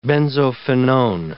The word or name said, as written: benzophenone